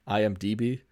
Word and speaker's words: IMDb: Oh, I was going on IMBD. – IMDB? – Yes, I literally say it wrong every single time I say it.